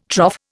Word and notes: trough: Indian

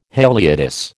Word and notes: haliotis: American English

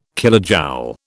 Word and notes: kilojoule: British English

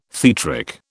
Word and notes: theatric: British English

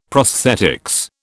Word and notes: prosthetics: British English

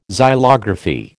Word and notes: xylography: American English

